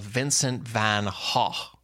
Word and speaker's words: Vincent van Gogh: I think the actual Dutch pronunciation is along the lines of Vincent van Gogh. And you don't say the G on the front. Gogh is the tricky last word there.